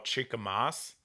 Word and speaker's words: Chikamasa: So I actually do, and it is a little snobby, high end, but there's a company called Chica Moss, which is one of my favorite. You guys can look them up online. It's actually a Japanese pruning company. – Cool. – And they make scissors. It's spelled Chikamasa scissors.